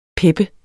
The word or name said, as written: pep